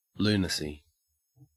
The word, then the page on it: lunacy: Australian English